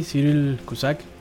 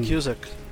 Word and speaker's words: Cyril Cusack: Y en el caso está John Hort, eh, Richard Burton, Susana Hamilton y Cyril Cusack, creo que lo pronuncié mal, pero voy a dejar que también... Cyril Cusack.